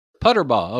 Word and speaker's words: Puterbaugh: And so is it Puterbaugh? – Well, that depends on who you ask. I pronounce it Puterbaugh. – Puterbaugh, okay.